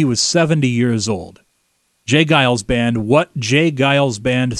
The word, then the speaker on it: Giles: Did I mispronounce the name of this band? – You did. It's the Jay Giles Band. – I said Gales.